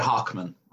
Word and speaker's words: Hochman: Yeah, you say it Hockman. Uh-huh. Hockman. Like the superhero Hawkman, but unfortunately it's not spelled the same.